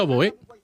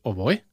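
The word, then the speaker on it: oboè: Pues mira, Xavi, por ti acabo de descubrir que en catalán oboe se pronuncia oboe, porque te estaba escuchando, digo, ¿por qué pronuncia con la acento en la E? Y claro, lo he buscado y efectivamente en catalán es oboe, ¿verdad? – Exactamente, sí, sí, correcto, ni tan siquiera lo había pensado, pero sí, de hecho, correctamente, pronunciado correctamente en términos fundacionales, en catalán sería uboe, uboe, porque la O inicial...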